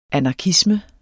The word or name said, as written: anarkisme